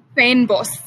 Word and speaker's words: fynbos: That's, you know, South Africa, Finbos is the word, right? You've taught me that. – Yeah. – Am I saying it right? – Fynbos. – Fynbos.